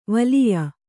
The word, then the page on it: ವಲಿಯ: valiya